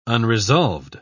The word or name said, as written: unresolved